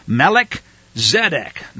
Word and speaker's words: Melchizedek: According to the order of Melchizedek. Now, you notice how I pronounce that. Melech, the Hebrew word for king. Zedek, the Hebrew word for a righteous scholar or one who walks in obedience.